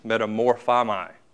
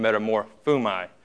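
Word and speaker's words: metamorphomai (μεταμορφόομαι): The way to pronounce it is metamorphomai, or metamorphumai.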